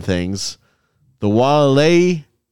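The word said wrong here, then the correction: Waialae: Golf Club. – Close. It's dang it. – Walleye. – I have to fire my secretary. She keeps giving me the pronunciations. – Walleye. – Like the fish. – Similar to the fish. You just kind of have to say it like you're a little tipsy.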